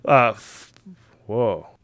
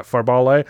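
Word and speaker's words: Fárbauti: His father is the giant Forbale. – Forbale? – It's weird. It does not look like it should be pronounced that way, but I looked it up.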